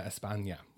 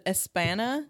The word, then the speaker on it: España: República de Nueva España. – Yeah. Thank you for saying España, because someone pronounced it Espana or Espana. And I was like, there's no Ñe on that.